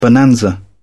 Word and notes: bonanza: British English

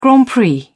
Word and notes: grand prix: British English

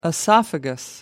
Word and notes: esophagus: British English

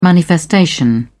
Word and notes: manifestation: British English

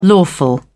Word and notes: lawful: British English

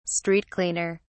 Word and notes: street cleaner: British English

